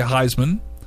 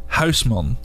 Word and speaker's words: Huisman: I tell people to just say Heisman. – Okay. – Like the trophy. – Yep. That I can say. – That's Heisman. – Just easier. – But I don't want to do that. I want to say it right. – Oh, but then you're going to be butchering it. – Exactly. – Yeah. All so right, it's so a Dutch last name. And it's you say Huisman.